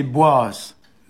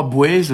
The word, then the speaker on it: Boaz: Et c'est Boaz. B-O-A-S ou B-O-A-Z selon les orthographes, qui se prononce dans certains endroits, Bouez aussi, et qui désigne l'habitude.